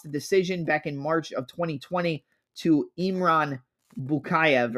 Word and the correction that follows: Bukuev: or Buku, Bukuev? Iman. Oh my God, I'm saying it wrong. Imran Bukuev at ACA 105.